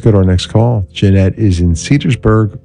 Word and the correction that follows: Cedarburg: Wisconsin. Cedarburg. Did I say that right? Yeah, Cedarburg.